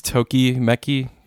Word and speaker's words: Tokimeki: For me, it would be Legend of the Galactic Heroes, Zeta Gundam Away to the New Type, and Tokimeki Memorial. I hope I pronounced at least some of those right. How'd I do? – I think it's Tokimeki. Tokimeki Memorial.